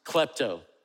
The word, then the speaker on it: klepto: The Greek word that's used for the word steal is a word that we would pronounce klepto. Can you say that with me? Klepto.